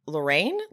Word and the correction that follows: Loryn: I think it's Lorraine. – Lorraine, right? It's just spelled Loryn.